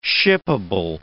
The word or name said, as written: shippable